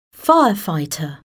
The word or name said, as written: firefighter